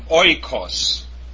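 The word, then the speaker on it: oikos (οἶκος): Omicron iota, pronounced oi, as in oikos, which means house in the English. Oi, oikos. Very important word in the New Testament.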